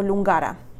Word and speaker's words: Lungara: o Lungara, como se pronuncia correctamente.